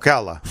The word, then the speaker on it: Ocala: Ocala? Is that how you pronounce it? – Ocala.